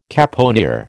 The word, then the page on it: caponier: American English